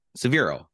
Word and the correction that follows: Savini: Tom Savini.